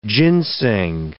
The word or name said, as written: ginseng